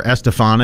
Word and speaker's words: Estefan: We have Miss Gloria Estefan, or Estefan if you prefer to pronounce it that way, 1957.